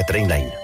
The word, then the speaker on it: Trainline: S'escriu millor preu garantit. Es pronuncia Trainline.